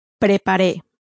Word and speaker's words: preparé: Preparé.